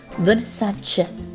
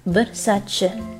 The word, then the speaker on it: Versace: Versace，很多的博主以及明星呢都会把这个 Versace 后面的 ch 读成了 ch 的音，但实际上根据这个范思哲家族的人来说呢，这个音应该是 Versace。 Versace。